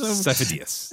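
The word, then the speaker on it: Sifo-Dyas: It's so Sifo Dyas.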